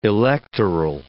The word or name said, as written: electoral